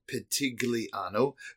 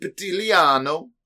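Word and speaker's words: Pitigliano: In English, you would phonetically say Petigliano, but the G is a silent, and it's called Petigliano.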